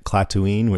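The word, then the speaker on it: Klatooine: And those guys are Clatooinian from the planet Clatooine, which I think rhymes with Tatooine, which is in Hutt space in the Outer Rim territories.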